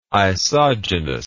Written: isogenous

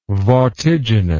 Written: vortiginous

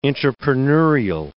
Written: intrapreneurial

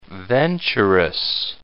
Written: venturous

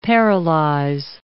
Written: paralyze

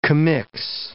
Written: commix

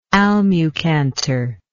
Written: almucantar

